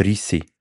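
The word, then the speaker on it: Ricci: Die heisst Rissi. Christina okay. Rissi. Ich glaube, ausgesprochen. Weißt du, so, dass ich es weiss? Weil ich eine andere Netflix-Serie habe geschaut, wo einer Richard Rissi heisst. Genau gleich geschrieben. Und dann haben sie es eben okay. So ausgesprochen. Habe ich noch mal Christina Rissi, nicht Riccio, gar nicht.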